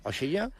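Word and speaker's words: Oshiya (押し屋): De fet, n'hi ha un que es diu el clàssic Oshiya, no, és que no, la meva pronunció és horror, Oshiya. – Oshiya? Sí.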